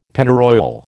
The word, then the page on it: Pennyroyal: American English